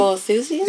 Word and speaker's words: Boethusians: Bo, I can't pronounce that word. Bo, um, Boethusians. – Yeah, those guys. Okay. Boethusians.